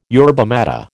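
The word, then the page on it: yerba maté: American English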